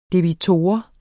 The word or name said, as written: debitor